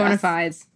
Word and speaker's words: bona fides: How do you say that? – Bona fides.